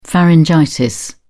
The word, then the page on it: pharyngitis: British English